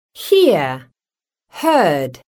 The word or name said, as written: hear-heard